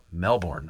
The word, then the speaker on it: Melbourne: I thought it was Melbourne, but yeah, like Melbourne. If you're Australian, you would pronounce it Melbourne.